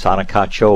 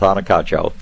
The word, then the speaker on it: Anacacho: I have, and I probably am going to say this wrong, an Anacacha orchid tree. – You came very close. Anacacho orchid tree. It's easier to say in Bauhinia congestus, so we'll stick with Anacacho.